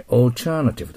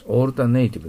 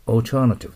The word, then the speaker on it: alternative: はい、 alternative と発音します。 alternative でなくて、 alternative と発音します。